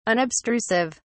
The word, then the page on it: unobstrusive: British English